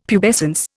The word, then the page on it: pubescence: Indian